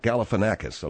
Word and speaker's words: Galifianakis: Oh, you can't even pronounce... – Alakazam. – Not Alec. Where do you get Alakazam? – Oh, I thought that was... – That's not his name. It's not Zach Alakazam. It's Zach Galifianakis, okay? – Yeah, well, now that you look.